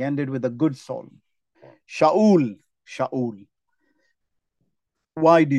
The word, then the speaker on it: Shaul: That is how it's pronounced, by the way, in Hebrew, Shaul. I will be the last Shaul.